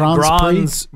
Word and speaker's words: Grand Prix: Is that Grand Prix? That's how you say it? – Yeah, Grand Prix.